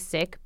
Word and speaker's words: Sikh: I mean, the majority of people know us as Sikhs. That's probably okay. What you've been saying. The correct pronunciation is Sikh, but a lot of people say Sikh just to get not confused with S-I-C-K.